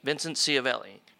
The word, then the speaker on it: Vincent Schiavelli: Vincent Schiavelli.